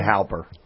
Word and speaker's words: Halper: That mole, as broken here last week by Rich McFadden, is it Stefan Haller that we say his name? – Stefan Halper. – Stefan Halper.